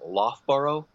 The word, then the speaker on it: Loughborough: I think it's pronounced Loughborough, England, because they knew that they were going to want to reproduce this and they want to bring it on stage.